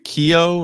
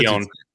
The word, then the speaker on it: Keoghan: How do you say, is it Barry Keogh? Or how do you say Keown. – Keown.